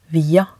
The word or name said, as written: via